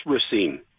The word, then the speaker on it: Racine: It's Racine.